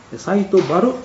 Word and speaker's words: 西都原: はい、九州とか沖縄の方ではこの原というのは春とかバルという発音をするんですね。はい、サイトバル古墳群です。ちょっと普通の発音とね、まあ、違う発音の仕方です。